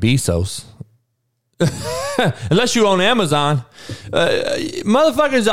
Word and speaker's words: Bezos: Andrew Arnold said Bezos, so that's how you pronounce his name.